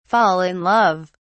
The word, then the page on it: fall in love: British English